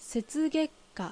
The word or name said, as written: Setsugek